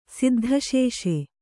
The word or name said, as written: ಸಿದ್ಧಸೇಸೆ